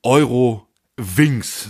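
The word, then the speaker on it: Eurowings: Euro Wings, sage ich mal, so wie sie sich selber aussprechen.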